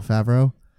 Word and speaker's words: Favreau: And I also very much enjoy John Favreau. How do you pronounce the last name? – Favreau.